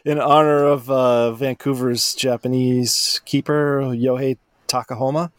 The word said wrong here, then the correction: Takaoka: I believe that's how you pronounce his name. – Takahoma. – Yeah. Takaoka? – Yeah. – Takaoka. Sorry. Yohei Takaoka.